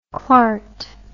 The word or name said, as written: clart